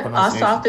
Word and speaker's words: Ossoff: And if am I pronouncing his name right? I always get his seem to get his second his last name wrong. What's do you know what? – Yep, Ossoff is correct.